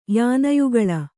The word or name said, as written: ಯಾನಯುಗಳ